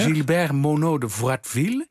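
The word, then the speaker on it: Gilbert Monod de Froideville: Gilbert Monod de Vratville. Zeg ik dat goed? – Helemaal correct.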